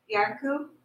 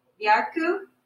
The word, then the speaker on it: Bjarku: Question on YouTube from Bjarku. – Who? – Bjarku. – How do you spell that? – B-J-A-R-K-U.